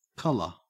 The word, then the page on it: colour: Australian English